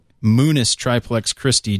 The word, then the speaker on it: Munus Triplex Christi: The Latin phrase is, Jeff's corrected my pronunciation here, is Munus Triplex Christi.